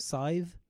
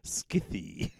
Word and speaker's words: Scythe: How would you say it? – Scythe. – Scythe. I nearly said Skithy. – Oh yeah, Skithy works too. – So Scythe is better than Skithy.